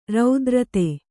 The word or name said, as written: ರತಾರ್ಥ